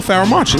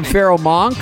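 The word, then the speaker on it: Pharoahe Monch: Also, Feral Monch. Like, I said his name wrong for like 10 years. – Right. – I just didn't know. – What'd you call him? Feral Monk? – Monk. I did.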